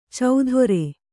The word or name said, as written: ಚೌಧೊರೆ